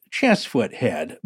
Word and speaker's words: Cheesefoot Head: I feel it would be remiss of me not to point out that Cheesefoot Head is actually pronounced Chessfoot Head by true locals. I was mocked by a local farmer the first time I said that incorrectly, and even most locals don't seem to know the correct pronunciation.